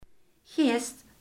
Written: gerst

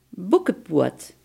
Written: bokkenpoot

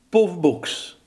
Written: pofbroek

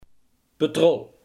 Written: petroleum

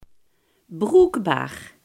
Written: bruikbaar

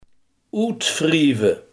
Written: uitwrijven

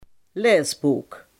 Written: leesboek

